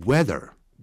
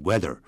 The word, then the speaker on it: whether: 在美式英语里头啊，念成 weather， W H E T H E R， W H E T H E R，念成 weather。